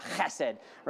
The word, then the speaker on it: chesed: But one that maybe you've heard before is this word hesed, or if we were to pronounce it, right? I'm not a Hebrew, I can't speak Hebrew really well, but chesed, right?